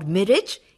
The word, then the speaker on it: mirage: V-I-N-T-A-G-E, vintage. There are some other words that also end in A-G-E but pronounced differently. M-I-R-A-G-E is not mirage or mirage, it is mirage. G-A-R-A-G-E is garage. C-O-L-L-A-G-E is collage.